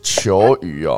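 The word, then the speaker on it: 犰狳: 哎，这两个字到底怎么念？ – 求鱼啊？ – 求鱼是不是？嗯，求鱼哦。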